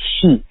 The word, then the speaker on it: Guangxi: But this idea of Guangxi, I'd like to hear more about it. Did I say that right? – Yeah. G-U-A-N, and then new word, X-E. And X-E in Mandarin is pronounced Xi, Right.